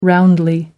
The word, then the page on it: roundly: American English